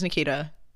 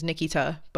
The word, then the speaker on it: Nikita: Like, where, uh, uh, whoa, my name is Nikita. Actually, my name is Nikita, but I've taught everybody how to say my name wrong for years because if I heard somebody say Nikita wrong, I think I, um, I'm gonna get real offended.